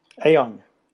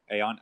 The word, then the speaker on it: Aon: on your website. I believe this is how you pronounce it. Is it AON or is it on? – AON. – AON. Okay. AON.